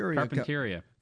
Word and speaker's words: Carpinteria: Carpinteria.